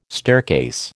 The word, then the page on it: staircase: American English